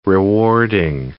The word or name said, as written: rewarding